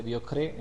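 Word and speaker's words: Diocrès: El profesor Raymond Diocres con S al final, se pronuncia Diocres en francés porque la S no se pronuncia, era un gran catedrático de la Universidad de París en el siglo XI, exactamente en el año 1089.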